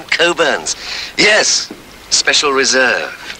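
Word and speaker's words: Cockburn's: Although it's spelt Cockburns, they pronounce it Coburns.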